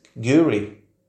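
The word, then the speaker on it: K'gari: The island's earliest known name is Kigari, pronounced Kiguri in Bajdala.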